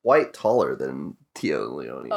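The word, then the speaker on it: Téa Leoni: Don't know how to pronounce her name. – Tia Leone. – Tia Leone? – Sure.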